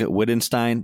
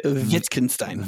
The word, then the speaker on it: Wittgenstein: Ludwig Wittgenstein. That's not how you say it, but he's. – Yeah. In German, W's are V's. So it's Ludwig Wittgenstein.